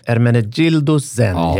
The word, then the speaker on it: Ermenegildo Zegna: det är ju Ermenegildo Zegna.